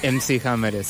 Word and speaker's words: MC Hammer: Mac Hammer. – Sí. Sí. MC Hammer. – ¿Pronuncié bien? – MC Hammer.